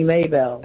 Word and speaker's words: Mabel: Yes, it's spelled M-A-B-E-L. – Okay. Well, thank you for calling. – But my parents, they call me Mabel, but it's really Maybell.